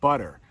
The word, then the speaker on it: butter: And an interesting thing about butter is that it is pronounced with two T's, but like many words in English that have T's in them, especially in the middle of the word, we actually pronounce it with a D, butter.